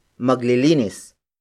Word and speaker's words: maglilinis: The next word is maglilinis. Maglilinis. Maglilinis. Maglilinis is spelled as M-A-G-L-I-L-I-N-I-S.